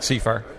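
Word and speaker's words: Sefar: At the Corn Utilization Technology Conference, I'm visiting, and it's CIFAR, is how you pronounce it? – That's correct, CIFAR.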